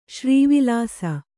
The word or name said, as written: ಶ್ರೀವಿಲಾಸ